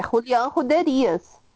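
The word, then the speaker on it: Julián Juderías: Cara, gente, tem acento, é Julian Ruderías.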